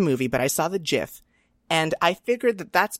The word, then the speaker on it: GIF: GIF. It's a hard G. It stands for graphic.